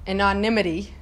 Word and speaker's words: anonymity: but his anonymity, did I pronounce that right? – Yep. – His anonymity freed him from the stupid stuff, wasting time on stupid stuff.